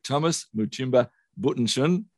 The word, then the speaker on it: Thomas Buttenschon: Thomas Buttenschon.